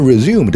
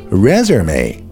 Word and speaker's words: resume: 同时，如果 resume 的读法变了，读成了 resume，就成了名词，简历的意思。